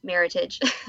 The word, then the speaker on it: Meritage: So they kind of created Meritage, which is merit and heritage. So it's always pronounced Meritage.